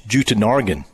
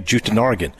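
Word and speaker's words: Jutanugarn: She says, "It's Jutanargan." Okay, Jutanargan.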